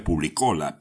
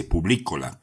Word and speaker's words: Publícola: Yo decía Publícola, pero en realidad se dice Publícola, con un acento en la letra I, y eso proviene del latín servicio al público.